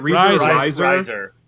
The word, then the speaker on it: Reiser: Well, and then there was a real dark one too, where Paul Reiser, right? Is that how you say it? – Reiser.